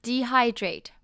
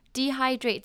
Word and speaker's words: dehydrate: 那 dehydrate， dehydrate 这边呢，它的重音是在中间 HY 的部分， dehydrate， dehydrate，那 Y 的发音不变，只是重音变了。